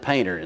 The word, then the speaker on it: panther: Now, if you spell it out, it looks like panther, but you pronounce it painter in the mountains.